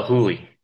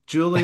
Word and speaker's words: Juli: J-U-L-I. – Huli. Oh, yeah. Okay. Julie. Huli. – Sorry. Yeah. We call it, it's Huli, just because it's in Colombia, that J is a soft J. So yeah. Like in Mexico, it'd be a hard J. Here for us, we call it Julie, so it's a hard J. But for some reason, Colombian Spanish, it's a soft J, so it becomes Huli.